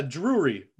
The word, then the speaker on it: Drewry: I honestly don't know if I'm saying that right. – That's a separate person though, right? That wasn't you mispronouncing John Jr. again. That was that is a separate person. I'll spell it out for you. It's Drewry.